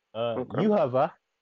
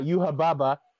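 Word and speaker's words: Yhwach: Yuhava from Bleach. It's not Yuhababa. The English translation is incorrect. That's how you say it in Japanese because they don't have V sounds over there. Actually, Yuhava.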